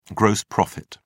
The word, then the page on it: gross profit: British English